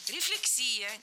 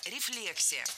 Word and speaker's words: рефлексия: Рефлексия. Неверно. Правильно, рефлексия. Ударение ставим на второй слог, как и в исходном существительном. Рефлекс, рефлексия.